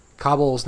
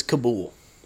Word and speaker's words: Kabul: Kabul's now. – Is that how you pronounce it? – Yeah. – I've been reading it as Kabul.